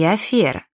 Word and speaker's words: афера: Если бы при написании «е» от «ё» отличали, то мы бы точно знали, что правильно говорить «гренадер», «бытие» и «афера». Произношение этих слов через «ё» — весьма распространенная ошибка.